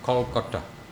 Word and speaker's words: Kolkata: But presently he came to settle in Calcutta, called now Kolkata. Which is basically Bengali pronunciation of Calcutta.